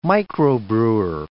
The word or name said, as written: microbrewer